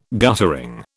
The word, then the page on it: guttering: British English